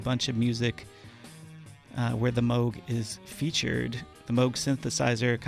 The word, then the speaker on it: Moog: It is the Bob Moog. Or as likely a lot of people pronounce it, Moog.